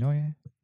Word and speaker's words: Neue: New Neue Classics. Is that how you say it? – Yep. – Neue.